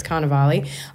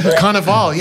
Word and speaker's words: Cannavale: I just realized I pronounced that wrong at the start. I said Cannavale.